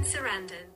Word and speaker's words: Sarandon: Susan Sarandon.